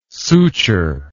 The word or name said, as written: suture